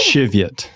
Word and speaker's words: Cheviot: Did you pronounce that right? – Did I? – Cheviot. – Yeah.